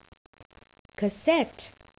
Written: cassette

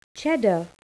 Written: cheddar

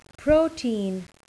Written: protein

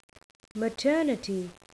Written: maternity

